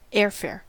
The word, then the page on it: airfare: American English